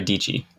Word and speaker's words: Dietschy: Uh, Sara Dietschy. Yeah. That's her. Rhymes with peachy.